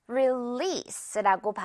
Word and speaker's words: release: 이것도 조금 어려울 수 있는데 release라고 발음되는 동사입니다. SE 발음. 자, 이건요. 출시하다. 대표적인 뜻이 그거죠. 출시하다. 내놓다. 역시 release라고 정말 많이 쓰는 말인데 release. S로 발음된다는 거 기억을 해야겠죠.